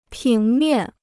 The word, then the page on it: 平面: píng miàn